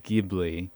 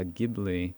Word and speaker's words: Ghibli: Ghibli is an Italian word. And I guess in the original Italian, it's pronounced Ghibli. So no fault if you pronounce this term Ghibli.